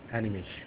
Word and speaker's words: Animesh: Animesh.